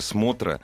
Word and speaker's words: Смотра: что такое Смотра. Я неправильно ставил ударение. Смотра.